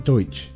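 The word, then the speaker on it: Deutch: D-E-U-T-C-H. I looked how to pronounce it. Deutsch. Hmm. Howard Deutsch and Leah Thompson fell in love while filming this film.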